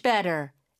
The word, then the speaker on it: better: 네, 빈칸에는 Better, B-E-T-T-E-R. – 네, better, T가 두 개 들어가지만 물론 하나만 발음하고 모음 사이에 껴서 리을처럼 부드럽게 발음이 되죠.